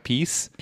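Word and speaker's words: PiS: Mm-hmm. Piss. I'm, I'm, upset, I'm upset to find out now that it's pronounced what peace. – Peace. Yeah, no, I know, but piss. I always say piss.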